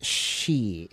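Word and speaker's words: 3HE: I'm going to close out the session of Bridging the Gap with, I think the group is pronounced She. It's 3-H-E. That's how it's spelled.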